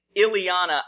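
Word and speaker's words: Illyana: So I've always said Ilyana, I believe, because I think it's Ilyana. Meant to be the same pronunciation as the Russian name.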